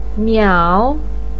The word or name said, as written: miao3